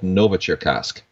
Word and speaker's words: Novocherkassk: at a factory in a city called Novocherkassk. Can't believe I pronounced that correctly. Yeah, that sounded uh, perfect.